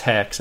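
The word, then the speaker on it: HECS: so that's Hex, that's how you spell it, H E C S L L C dot com.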